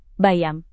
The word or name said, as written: bayam